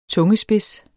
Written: tungespids

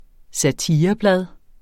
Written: satireblad

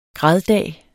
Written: graddag

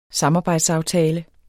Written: samarbejdsaftale

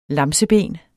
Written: LAMSEBEN

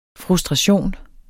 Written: frustration

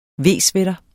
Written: V-sweater

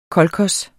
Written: kolkhos

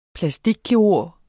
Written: plastikkirurg

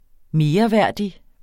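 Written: mereværdig

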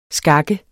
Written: skakke

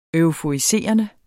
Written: euforiserende

